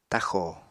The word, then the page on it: taxò: apatani